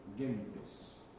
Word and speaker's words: gentes: gentes. En latín no existe la J, ¿eh? O sea que toda pronunciación de esta G seguida de una E como J, sui generis, no es latín, es una cosa extraña, es sui generis, ¿no?